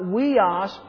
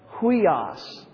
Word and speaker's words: huios: A left single quotation mark, okay, or an apostrophe that goes towards the word, it is pronounced with an H sound. And here's two words, hopefully you can see them. This is not weos, but huios, okay?